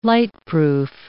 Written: lightproof

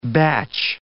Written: batch

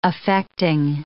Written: affecting

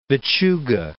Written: Vichuga

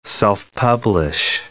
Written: self-publish

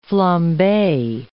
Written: flambe

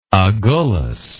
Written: Agulhas

